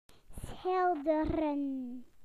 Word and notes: to paint: British English

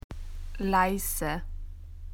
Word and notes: quiet: Australian English

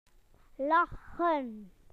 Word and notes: to laugh: British English

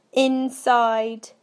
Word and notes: inside: British English

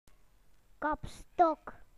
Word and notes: coat rack: British English

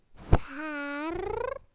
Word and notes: scissors: British English

